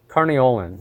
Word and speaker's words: Carniolan: Uh, carnelian Carniolan, excuse me, I always pronounce that wrong, uh, tend to be very dark bees.